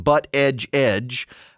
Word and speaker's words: Buttigieg: You know, Pete Buttigieg, as they say, that's how you technically pronounce his name, Buttigieg.